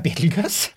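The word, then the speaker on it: Betelgeuse: Og da sier han aller først, hvordan uttales navnet på stjerna? Det har jeg da svart på, det er Betelgøs. Det er i hvert fall sånn vi vanligvis gjør det i Norge.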